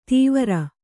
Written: ತೀವ್ರ

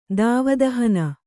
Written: ದಾವದಹನ

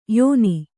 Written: ಯಾನ

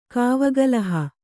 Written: ಕಾವಗಲಹ

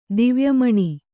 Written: ದಿವ್ಯಮಣಿ

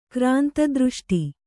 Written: ಕ್ರಾಂತದೃಷ್ಟಿ

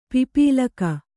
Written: ಪಿಪೀಲಕ

